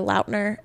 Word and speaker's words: Lautner: He said it's not Lautner and it's actually Lautner. – Oh, really? – And he said he just never corrected anyone, but that the correct like pronunciation is Taylor Lautner.